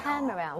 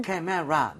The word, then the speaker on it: camera: She bought me 여기에만 강세 주면 돼요. – camera. 오. – camera. 근데 진짜 무슨 이름절인 것처럼 라, 라 이렇게 돼야 돼요. camera 나눠지는 순간 발음이 엄청 어색해집니다.